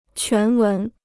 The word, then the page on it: 全文: quán wén